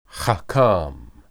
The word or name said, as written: hakam